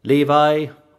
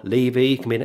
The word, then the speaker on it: Levi: Levi or Levi, it can be pronounced either way.